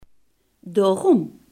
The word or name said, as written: daarom